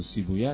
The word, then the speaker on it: سیبویه: من شاید بهترین خاطره که امضا گرفتم از عالم بزرگی به نام آقای سیبویه که بعض سیبوی تلفظ میکنه که اسمش سیبویه است، تلفن زدم و اجازه گرفتم تایید فرمودم.